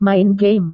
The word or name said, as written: main game